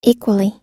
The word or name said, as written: equally